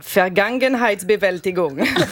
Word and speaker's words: Vergangenheitsbewältigung: Deuxième indice, ça se prononce Vergangenheitsbewältigung.